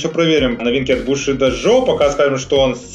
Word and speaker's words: Bushido: Да, потому что Бушидо тоже тоже читается как бусидо.